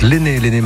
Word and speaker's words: Lene: Ça s'écrit L-E-N-E, mais ça se prononce l'aîné Marlin en finlandais, norvégien, je sais plus, faut que je vérifie.